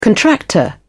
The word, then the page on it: contractor: British English